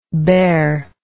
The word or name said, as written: baire